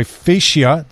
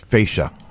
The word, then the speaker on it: fascia: 708 texter, my fascia. Did I say that right? – No, it's fascia.